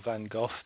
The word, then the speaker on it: Van Gogh: Yes, indeed, and it was shortly after that movie was made, of course, that Theo Van Gogh, or Van Gogh, depending on your pronunciation, was murdered in the streets in the Netherlands.